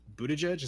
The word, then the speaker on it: Buttigieg: I'm really glad to see people like how do you say his name? – Buttigieg, is that right?